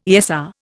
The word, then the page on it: ESR: Indian